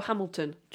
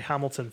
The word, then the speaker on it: Hamilton: Yeah, I like that the French people don't say Hamilton for some reason. – Hamilton. – Hamilton. – Well, we don't pronounce consonants at the start of words, and we don't pronounce consonants at the end of words with the French.